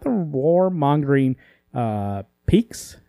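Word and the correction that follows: Pykks: I forgot how do you say that again? P-Y-K-K-S? Peaks? Whatever. – Pikes.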